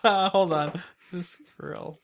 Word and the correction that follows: visceral: I said it correctly. Wait, that's not volume. Visceral. Visceral. Visceral.